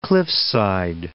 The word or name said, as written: Cliffside Park